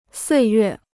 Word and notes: 岁月: suì yuè

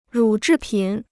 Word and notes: 乳制品: rǔ zhì pǐn

